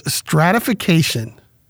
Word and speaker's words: stratification: The word of the day is stratification. Five syllables. It's a noun.